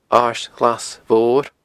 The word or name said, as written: Aird Ghlas Mhor